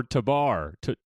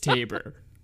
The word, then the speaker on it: Tabor: Is it Tabor or Tabar? Tabor? – I mean, Tabar... Tabor sounds more exciting, I think, but I do think it's Tabor.